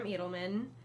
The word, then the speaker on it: Edelman: But these are Sam Edelman. They were... – Oh, that's how you pronounce it? I've been saying Edelman.